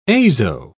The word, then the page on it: azo: British English